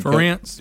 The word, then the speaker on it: Ferentz: They also demand that athletic director Gary Barta, and I don't know how you say Kirk's last name, the head Ferentz.